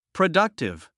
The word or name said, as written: productive